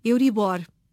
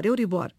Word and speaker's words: Euribor: Filomena, em português, a pronúncia correta é Euribor, palavra aguda. – Uhum. Mas tem acento no O ou não? – Não, não, não. Okay. Não. Pronúncia Euribor.